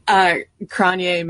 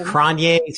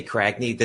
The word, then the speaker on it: Cragne: Cranye Manor is my pronunciation. – Yeah, it's Cranye. Some people say Cragney. This is a project led by Jenny Polodna and Ryan Veter last year, and it is spelled C-R-A-G-N-E, Manor, as in a manor house.